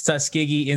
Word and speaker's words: Tuskegee: and how do you say it? – Tuskegee Institute, Rivers said.